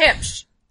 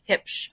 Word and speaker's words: Huebsch: But it's Hipsch. H-U-E-B-S-C-H. It's like your hips, you know, like where your belt goes, and then shh, be quiet. Hipsch.